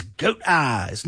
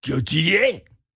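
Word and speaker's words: Gotye: That is goat eyes. Nope, you pronounce it Gautier because he's from Belgium.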